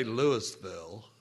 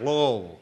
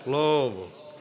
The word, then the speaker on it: Louisville: I didn't say Louisville or Louisville. It's pronounced Louisville. Louisville.